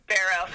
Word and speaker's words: Barrow: It's Barrow.